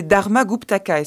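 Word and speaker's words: Dharmaguptaka: Alors, c'est un extrait des Vinaya Pitaka des Dharma Guptaka. Est-ce que je le prononce bien ? – Bravo !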